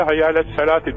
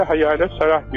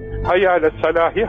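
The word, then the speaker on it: hayyâle salâti (حي على الصلاة): İşte bu T harfi geçildiği zaman T okunur, durulduğu zaman H okunur da ondan. Şimdi bunu bilmiyor. Efendim, kardeşlerimiz kâhmet getirirken hayyâle salâhi, hayyâle salâhi diyor. Hayır. Yani geçildiği zaman hayyâle salâti.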